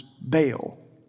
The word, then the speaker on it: Baal: The most famous is Baal, which we most commonly say pronounces Baal.